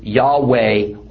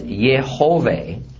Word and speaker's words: Yehovah: The commonly held pronunciation is Yahweh or Yahweh. I favor the pronunciation as Yehovah as most recent evidence is that the word consisted of three syllables.